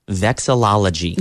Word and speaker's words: vexillology: Vexillology.